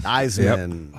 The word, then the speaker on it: Theismann: Theismann. Yep. – Oh. Rhymes with Heisman.